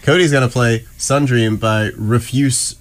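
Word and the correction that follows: Rüfüs: de Soul. – Rufus yeah. is how you say it.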